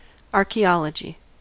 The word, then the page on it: archaeology: American English